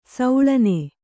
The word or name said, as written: ಶಾಲಣ